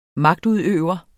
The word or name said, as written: magtudøver